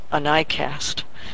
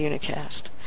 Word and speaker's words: unicast: By the way, Jaws pronounces it anicast, but it's actually unicast.